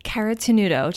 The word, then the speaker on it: Caratenuto: Caratenuto, just like it's spelled.